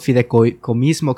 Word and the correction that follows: fideicomisos: ¿cómo se dice? Fideicomisos.